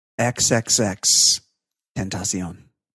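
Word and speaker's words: XXXTentacion: The 18th, I don't know how to pronounce this, Triple X Tentacion? Is that, what is it? X-Tentacion. – Let me step in. XXX Tentacion.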